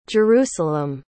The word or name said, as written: Jerusalem